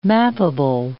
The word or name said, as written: mappable